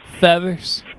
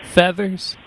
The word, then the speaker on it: Feathers: C-O-L-B-Y? – Yeah. – All right, what's the last name, Kobe? – Feathers. – Say that one more time. – Feathers. – How you spell that? – F-E-A-T-H-E-R-S.